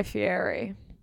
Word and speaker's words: Fieri: And you might be thinking, why are you saying Fieri, Fieri? That's how he pronounces it, and I respect the king.